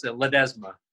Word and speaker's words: Ledesma: Ledesma, is that correct? – It's close. Ledesma. – Ledesma. All right, a little different emphasis.